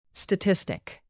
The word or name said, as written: statistic